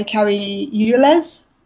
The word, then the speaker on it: Cary Elwes: Whereas the idea of, like, at the beginning of the film, Dr. Gordon, played by Cary Elwes, is that how you pronounce it? – Yeah, he's Wesley.